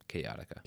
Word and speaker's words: Kaotica: Bro, that's how you say that? Chaotica? – Chaotica. – K-O-U-T-I-C-A. – K-A-O-T-I-C-A, yeah. – Gotcha. Chaotica. I've been saying it wrong.